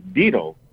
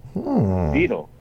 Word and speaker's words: daedal: So the word is deedle. Deedle. And it's spelled D-A-E-D-A-L. But it's pronounced deedle.